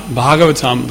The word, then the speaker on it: Bhagavatam: And this is Bhagavatam in Sanskrit means Bhagavatam, the long A means it's about Bhagavan, the small A.